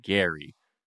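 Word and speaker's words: Gerry: whose name is spelled like Jerry, but pronounced Gary, to experience the whole bachelor process with.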